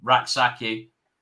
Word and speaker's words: Rak-Sakyi: Rak-Sakyi. No, you got it right.